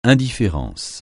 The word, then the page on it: indifférence: francais